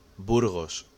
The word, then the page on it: Burgos: Spanish